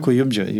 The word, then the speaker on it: Kuyumcu: Kuyumcu.